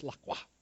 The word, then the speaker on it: La Croix: Is that how you say it? Oh, how do you say it? Come on, help me. La Croix. La Croix. Sorry, that's just how I've always said it, but it's La Croix.